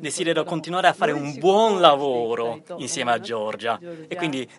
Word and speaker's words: buon lavoro: Io ho saputo che quando in Italia ci si accommiata da chi è impegnato nel proprio lavoro, lo si saluta dicendo buon lavoro, spero che la pronuncia sia giusta. Io ammiro, grazie. – Buon lavoro. – Buon lavoro, ecco.